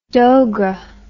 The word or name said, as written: dogra